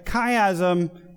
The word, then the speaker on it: chiasm: And it comes from the Greek letter chi, or key. So it's spelled C-H-I-A-S-M, chiasm.